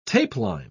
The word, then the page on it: tapeline: British English